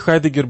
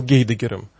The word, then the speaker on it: Хайдеггер: Вот для них Хайдеггер был Гейдеггером. Но сейчас правила изменились, и мы все больше подражаем естественному произношению в немецком, французском, английском языке.